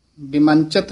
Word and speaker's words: बिमुंचित: ताव पै बिमुंचित इथे ठमक बिमंचित नहीं कहना ताव पै बिमुंचित साध संगम इथे विश्राम ओट नानक नारायण है हे काल मूल क्रोधं कदंच करुणा न उपर्जते।